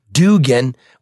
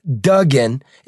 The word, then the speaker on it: Duggan: The people still called him Dugan when it was actually pronounced Duggan, exactly the same pronunciation as Patrick J. Duggan, a federal court judge who also happened to be the mayor's father.